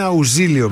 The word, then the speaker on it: Αουζίλιο: Νομίζω ότι η σωστή προφορά του επιθέτου του Αουζίλιο είναι Αουζίλιο με ζήτα, όχι με σίγμα. Όχι Αουζίλιο.